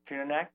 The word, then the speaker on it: Tununak: Was it pronounced Tununak or Tununak? – Tununak, yeah.